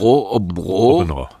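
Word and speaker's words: Aabenraa: Obenrå, oder wie spricht man es aus? – Obenrå. Das ist Abenrade etwas leichter.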